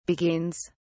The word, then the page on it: beguines: British English